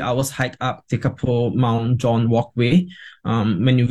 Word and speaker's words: Tekapo: And then there's this adventure playground at Lake Tekapo, T-E-K-A-P-O. – Tekapo, yep. – Tekapo, is it? Is that how it's pronounced? – That's right.